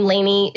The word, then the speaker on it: Lainie: And so for those listening, my first name, Lainey, is L-A-I-N-I-E. And it's Rowell, like Powell, R-O-W-E-L-L.